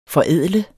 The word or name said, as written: forædler